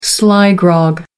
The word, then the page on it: sly grog: American English